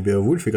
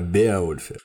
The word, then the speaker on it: Беовульф: Но ну, сразу оговоримся, что мы будем говорить не о Беовульфе какого-то там непонятном, а о Беовульфе. В англосаксонском эпосе Беовульф который на самом деле Беовульф. Все имена собственные, а также топонимы произносятся с ударением на первый слог, на первую гласную.